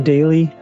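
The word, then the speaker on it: Dayley: Look for Alan Daly. And it's best to look for Alan Daly and include the term Agile in there, because there is another pretty well-known Alan Daly spelled the same way, but that's not me.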